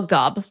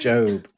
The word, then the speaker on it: GOB: Well, Gob, let's do this. – I don't like that. It's pronounced Job.